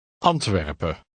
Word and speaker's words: Antwerpen: Take special care to stress the first syllable of the following place and country names. Read number one aloud. – Antwerpen.